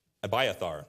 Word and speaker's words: Abiathar: After David dies, Solomon turns to Abiathar. That's how you say it. Thank you, pronunciation marks. Abiathar, the priest, said the king, get thee to Anathoth, unto thine own fields, for thou art worthy of death.